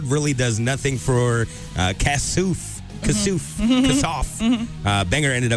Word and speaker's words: Kassouf: I guess it's Kassouf. I thought it was Kasoff.